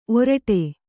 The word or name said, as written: ಓರಿಡು